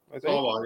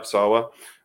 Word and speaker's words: Sawa: Sawa, yeah. – Yeah, Sawa.